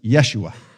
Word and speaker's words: Yeshua: So Jesus in Hebrew was Yeshua. And we just, in English, we pronounce the J, a hard J, so we say Jeshua.